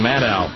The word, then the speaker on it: Maddow: will be on Rachel Maddow like rhymes with shadow, will be on Rachel Maddow this evening.